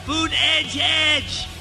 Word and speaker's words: Buttigieg: Buttigieg. Food edge edge. You say food? And then add edge edge. Food edge edge.